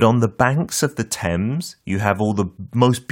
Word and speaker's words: Thames: 그 스펠링대로 읽으면 안 돼요. 참고로 T H A M E S. – Uh-huh. Thames라고 안 읽고 어떻게 한다고요? – H가 묵음이라서 Thames.